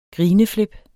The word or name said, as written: grineflip